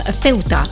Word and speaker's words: Ceuta: Zo moet ik het uitspreken, Ceuta.